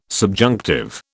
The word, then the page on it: subjunctive: British English